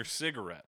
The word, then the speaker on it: cigarette: They snap their teeth on your cigarette, which rhymes with bet.